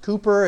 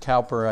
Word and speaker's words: Cowper: So John Newton and William, and it's pronounced Cooper if you haven't worked with him. If I said Cowper, I think my credibility would drop a little bit.